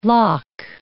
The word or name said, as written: loch